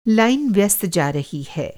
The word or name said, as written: लाइन